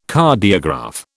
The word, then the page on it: Cardiograph: British English